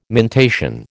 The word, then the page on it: mentation: American English